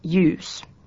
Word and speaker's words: ljus: Konsonanttiyhdistelmissä DJ, GJ, HJ ja LJ jäävät D, G, H ja L ääntämättä. – DJ esimerkiksi djur, eläin. GJ esimerkiksi jyyttä, valaa. HJ esimerkiksi jättä, sydän. LJ esimerkiksi ljus, valoisa.